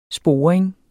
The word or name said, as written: SPORING